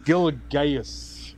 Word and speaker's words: Gilgeous: It's pronounced Gilgeous. Shai Gilgeous.